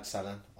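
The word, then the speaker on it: Sallan: And on Facebook, Raj Salan, I'll come up and you'll know straight away.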